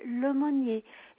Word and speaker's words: Lemonnier: Oui, alors je suis madame Chantal Lemonnier.